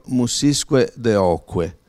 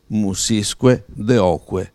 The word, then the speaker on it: Musisque Deoque: MQDQ is an acronym for Musisque Deoque, two words and six syllables, meaning offered to the muses and to God. Musisque Deoque.